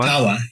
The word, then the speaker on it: Tawa: Tawa, yes.